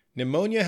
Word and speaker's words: pneumonia: Pneumonia has an odd spelling because we have a silent P. It's spelled P-N-E-U-M-O-N-I-A. But we don't say the P. It's said pneumonia.